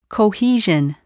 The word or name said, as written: cohesion